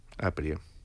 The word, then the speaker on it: Aprie: Aprie. – Con acento en la primera, en la...